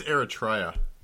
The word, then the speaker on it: Eritrea: Is it Eritrea or Eritrea? – I think it's Eritrea.